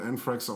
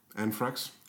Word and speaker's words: Anthrax: Anthrax. Anthrax? Ja. Spricht man das nicht Anthrax aus? TH, mein Freund.